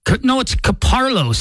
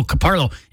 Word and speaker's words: Caparulo: Now I just had the person's name, John Caputo. Am I saying that right? It's Caparlo, C-A-P-A-R-U-L-O, Caparlo.